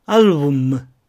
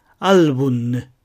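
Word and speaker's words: álbum: 最初の単語は、アルブムではなく、アルブンヌ。次の単語は、イスラムではなく、イスランヌ。で、実際には、ヌなんて言わないで、イスランというだけなんですが、このように発音します。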